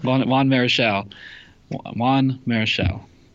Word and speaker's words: Marichal: How do you say it again? – Juan Marichal.